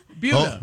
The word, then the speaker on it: Buda: Buda. – Buda. – It's spelled B-U-D-A, and I looked it up. It is pronounced like beauty.